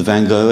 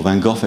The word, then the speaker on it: Van Gogh: We've been to the Van Gogh exhibition, or Van Gogh exhibition, depending on which pronunciation you favour.